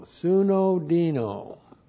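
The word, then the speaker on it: Sunodino (συνωδίνω): S-U-N, long O-D-I-N, long O. Sunodino.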